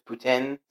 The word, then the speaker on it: poutine: And it's like, I know for a fact it's poutine.